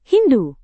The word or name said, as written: hindu